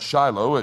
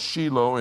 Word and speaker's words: Shiloh: They would go up to Shiloh. You pronounce that Shiloh. It's Shiloh in Hebrew.